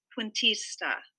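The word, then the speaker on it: Cuentista: Now, I did have to check the pronunciation with one of our Spanish interpreters, so I believe I'm pronouncing it correctly. It's called The Last Puentista.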